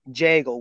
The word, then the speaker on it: Jagel: So basically, my last name is Jagel with a J. So it rhymes with bagel with the food item.